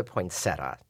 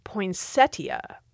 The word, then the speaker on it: poinsettia: I've called it a poinsettia. – Uh-huh. And that is a very common pronunciation in the U.S. There's also the pronunciation poinsettia, which some folks think should be the only pronunciation. Now, in theory, there is no T at the end of the first syllable, no matter how you say the end of that word.